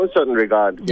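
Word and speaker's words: Un Certain Regard: And it was in the section of the Cannes Film Festival that's called Uncertain. I'm saying this wrong. How do you say it? – Yeah, Uncertain Regard.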